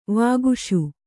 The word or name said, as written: ವಾಗೀಶ